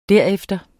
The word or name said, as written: derefter